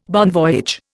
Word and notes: bon voyage: Indian

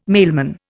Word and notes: mailman: Indian

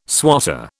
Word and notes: swatter: British English

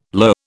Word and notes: lur: British English

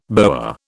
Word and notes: boa: British English

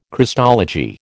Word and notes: Christology: American English

